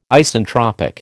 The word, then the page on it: Isentropic: American English